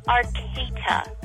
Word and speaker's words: Arcata: Arcata.